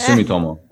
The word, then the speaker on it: Sumitomo: And hey, I know I mispronounced Sumitomo, and I apologize. – Yeah, yeah. It's Sumitomo, but you weren't that far off.